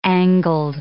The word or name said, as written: angled